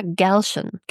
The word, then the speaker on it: Galchen: Galchen. Ich habe extra nochmal in einem Podcast nachgehört, wie sie sich ausspricht. Rivka Galchen. Galchen. – Galchen. – Ist ein bisschen okay. – Schwierig. Ist G-A-L-C-H-E-N. Galchen, würde ich sagen.